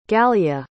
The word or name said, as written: galya